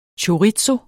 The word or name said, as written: Chorizo